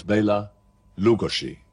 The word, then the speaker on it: Bela Lugosi: His name was Bela Lugosi. Now, that's the correct way of pronouncing it. But that was probably a little bit too much for people to take. And so he became immortalized under the name of Bela Lugosi.